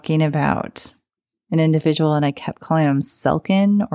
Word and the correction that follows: Selkirk: or something like that. But his name is Selkirk.